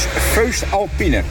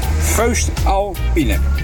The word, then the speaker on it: Voest Alpine: Ik zeg, hoe spreek je dat uit? Dat Voest Alpine. Nou, let op, daar komt het. – Dat is Voest Alpine. Voest Alpine.